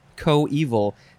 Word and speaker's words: coeval: And the synonym is our next word, which is pronounced coeval co evil.